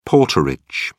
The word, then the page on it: porterage: British English